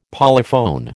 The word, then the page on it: polyphone: American English